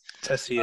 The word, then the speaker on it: Texier: Alexandre Texier, is that how you say it? – Yeah, pretty much. – Mess Texier. French names. – Tessier, yeah.